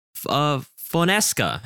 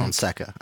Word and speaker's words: Fonseca: decided to go with Paulo Fonseca, I believe is how you pronounce it. – Fonseca, I think. – Fonseca, okay.